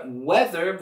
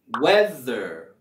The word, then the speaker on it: weather: Y en la palabra weather, vamos a subir la entonación en la primera sílaba. Weather, weather. Y ahí tenemos nuestro sonido TH sonoro. Weather, weather, weather. Se siente esa vibración.